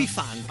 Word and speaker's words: P-Funk: P-Funk.